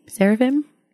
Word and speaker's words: seraphim: Seraphim? Which one is it? Seraphim? – Yeah. – So I said it right.